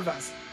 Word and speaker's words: Torvus: Torvus.